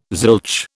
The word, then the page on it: zilch: British English